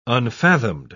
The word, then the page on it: unfathomed: British English